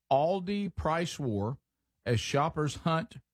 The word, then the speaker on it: Aldi: Aldi? – Yeah, mm-hmm.